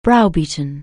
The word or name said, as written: browbeat